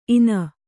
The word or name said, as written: ಇನ